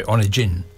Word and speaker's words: Onegin: It's Onegin, it's pronounced, I think.